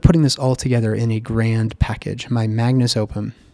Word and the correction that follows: magnum opus: magnum opus. We say it wrong, magnum opus.